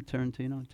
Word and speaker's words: Tarantino: You know, somebody told me that, how do you say his name? Tarantino? Did I say it right? – Sure.